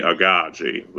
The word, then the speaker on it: Aghaji: Yeah, it's at Ogechi Aghaji